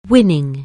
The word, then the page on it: win: anglais